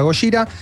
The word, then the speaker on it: Gojira: en, japonés Godzilla se pronuncia Gojira.